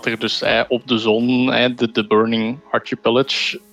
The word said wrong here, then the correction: Archipelago: was de juiste uitspraak? Archipelago. Archipelago, Archipelago ja.